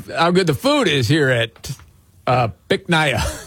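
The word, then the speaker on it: Bich Nga: you pronounce it Bic Naya. – Bic Naya? – Yes, but it's spelled B-I-C-H. One word. Yeah. Second word, N-G-A.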